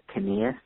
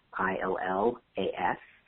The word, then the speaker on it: Canillas: Canillas, the last name is spelled CANILLAS, at thechicagoschool.edu.